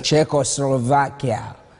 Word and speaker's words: Czechoslovakia: He was born in Czechoslovakia. I don't know if I said that one right. He will say it right. – Czechoslovakia.